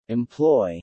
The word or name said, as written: employ